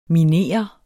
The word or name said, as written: MINERES